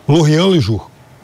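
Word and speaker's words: L'Orient-Le Jour: E aí, Luca, no jornal libanês, Lorient Lejour, né, que é um, enfim, um jornal importante no Líbano, há uma Como entrevista. É o nome do jornal? – Lorian Lejour. Mas é uma pronúncia impecável.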